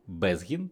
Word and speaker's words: Безгін: Сьогодні зі мною Віталій Безгін, правильно? Правильно. Читається?